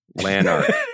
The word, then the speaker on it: Lanark: Yeah, and the second L is silent. – Lanark. But it's not like, it's not like a Spanish, it's not like a Yanark.